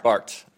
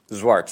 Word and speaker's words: Swart: Swart. Swart. – Swart? – Yeah, it's Dutch.